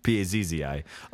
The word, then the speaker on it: Pazzi: Isn't it P A Z I? P A Z Z I. Oh, that's in Italian. That's Pazzi, isn't it?